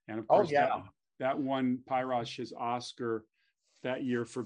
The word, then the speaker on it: Pirosh: Really, because Pyrosh, is the Hungarian word for red, Pirosh. That's how it's pronounced. It's a Hungarian name or Hungarian word, Pirosh.